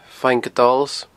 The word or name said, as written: Faing Adols